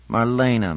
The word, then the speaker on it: Marlena: "Marlena, Marlena, Marlena, three syllables, accent on the second, grown up."